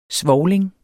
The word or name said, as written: svovling